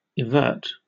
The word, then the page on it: evert: British English